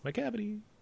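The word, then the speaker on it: Macavity: But McCavity, I honestly can't remember. – You said it right though, McCavity.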